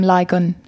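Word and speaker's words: Lygon: So yeah, so he was the seventh Lord Beecham the seventh, William Ligon is how I say his name, and that was in 1920.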